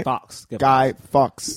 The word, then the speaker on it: Guy Fawkes: Guy, I'm saying the name wrong. Guy Fawkes. Right? – Guy Fawkes.